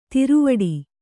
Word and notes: ದಾರವಟ್ಟ: Kannada

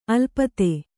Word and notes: ಅಲಿಪ್ತ: Kannada

